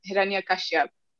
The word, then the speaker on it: Hiranyakashyap: And the story is essentially centers on a evil king, as they describe him, and his name, which somebody can correct me if I'm saying it wrong, but I think it's Hiranyakashyap, right? – Yeah.